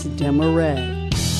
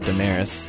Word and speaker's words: DeMaray: I was told my whole life we pronounced it Damaris until I started talking to some friends who were French, and they're like, dude, your last name is DeMaray. You should say it like that.